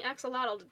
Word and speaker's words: axolotl: I guess we're also pronouncing axolotl it's wrong. – Um, the L. Okay, technically the L isn't silent. I'm just really bad at pronouncing the uh the TL.